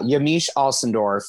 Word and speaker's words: Yamiche Alcindor: It's Yamiche Yamiche Alcindor from PBS.